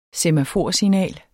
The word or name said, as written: semaforsignal